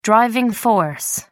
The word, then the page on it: driving force: American English